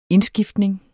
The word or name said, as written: indskiftning